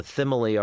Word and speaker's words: Thymele: Be at what I'm pronouncing the Thimile Art Center. It's a group of theaters that's at the corner of Western and Santa Monica. – Oh, fun. – Spelled T-H-Y-M-E-L-E. And it sounds like Mike Tyson saying simile.